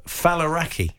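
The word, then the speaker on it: Faliraki: Your new show is called Falaraki. – Yeah. You pronounced it correctly. A lot of people don't.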